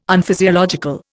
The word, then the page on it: Unphysiological: Indian